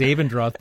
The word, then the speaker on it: Abendroth: It's kind of like my name is Abendroth, but it looks phonetically Abendroth.